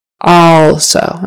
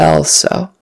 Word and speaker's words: also: It's a short O sound, even though it's spelled with an A. Also. Not also. Also.